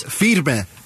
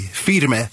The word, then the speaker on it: Firme: Firme. – Firme? – Firme. F-I-R-M-E. Firme.